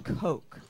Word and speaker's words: Koch: That what looks like cotch or perhaps cock is pronounced coke.